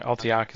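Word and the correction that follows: Alaitoc: is the Ranger one. – Oh, Alatok. – Alatok.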